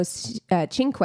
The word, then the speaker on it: Cinque: In 1995, Anu Singh and Joe Cinque, I had to write that out phonetically because it doesn't look like it's pronounced that way. – Right. – It is.